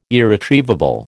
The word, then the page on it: irretrievable: American English